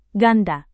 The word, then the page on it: ganda: Indonesian